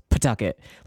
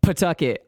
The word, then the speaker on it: Pawtucket: So everyone pronounces it Pawtucket. It's like here in Rhode Island, it's Pawtucket, like yeah. P-U-H apostrophe Tucket. So it's just like Pawtucket.